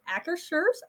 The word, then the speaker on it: Akershus: And this is in Epcot and I always mispronounce the name. So it's Akershus.